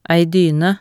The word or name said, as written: ei dyne